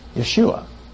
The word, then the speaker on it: Yeshua: They'll say Yeshua. Now, because of German influence, the Y became a J.